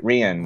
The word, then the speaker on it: Rian: Is it Rian Johnson, right? Rian. – Rian. Right? Rian yeah. Johnson.